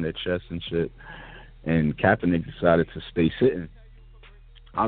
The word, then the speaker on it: Kaepernick: I think that's how you say his name. – Yeah, Kaepernick.